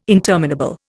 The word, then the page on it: interminable: Indian